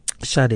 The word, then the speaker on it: Sade: That was called The Sweetest Taboo by, is it pronounced Sade? – Sade, yeah.